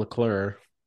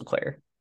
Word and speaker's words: Leclerc: I'm still going to call him Charles Leclerc. he's lucky that he gets that silent C at the end.